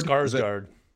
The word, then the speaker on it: Skarsgård: And how do you say his name? Stellan Skarsgård? – Skarsgård. – Yeah, I can never say it right.